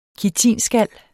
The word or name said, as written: kitinskal